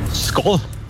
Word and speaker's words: Skål: Skål. – Wow. Richtig? – Echt? – Ja. – Oh. – Du hast sogar richtig betont. – Oh. – Wird Skål geschrieben, Skål, aber ausgesprochen.